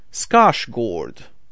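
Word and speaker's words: Skarsgård: Ну и так сразу оговорюсь немножко, что те, кто в теме, конечно же, знают, что Скарсгард — это не совсем правильное произношение его фамилии. У себя на родине его величают Стеллан Скашгорд.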